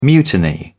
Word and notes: mutiny: British English

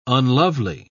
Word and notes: unlovely: British English

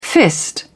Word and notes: fist: British English

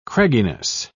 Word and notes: cragginess: British English

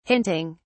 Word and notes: hinting: British English

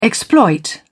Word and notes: exploit: British English

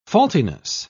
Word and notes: faultiness: British English

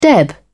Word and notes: deb: British English

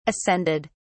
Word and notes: ascended: British English

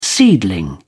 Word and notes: seedling: British English

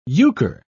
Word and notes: euchre: British English

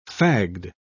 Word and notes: fagged: British English

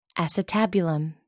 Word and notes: acetabulum: American English

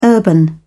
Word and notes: urban: British English

